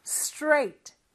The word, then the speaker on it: straight: rate, r이잖아요. rate, rate. 그 다음에 t를 살짝 넣어서 trait, trait. re가 가장 크게 들리죠, 여전히. 그게 되면 이제 s까지 넣어서 straight, straight. 이렇게 발음해 주는 거예요. 그래서 자음이 세 개지만 마치 하나처럼 발음이 돼야 되죠.